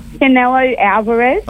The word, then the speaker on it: Canelo Alvarez: Canelo Alvarez.